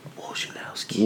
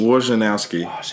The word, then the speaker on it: Wojnarowski: Wojnarowski. – Wojnarowski. – Not Woj. – I said Woj.